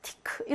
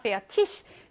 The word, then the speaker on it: fertig: fertig. 티크 이렇게 발음하는 사람들이 독일에도 너무 많은데 요거 표준 독일어 아닙니다. 페아티시 이렇게 발음하셔야 돼요.